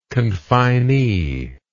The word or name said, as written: confinee